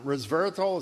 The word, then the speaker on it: resveratrol: Resveratrol, is that how you say it? – Right.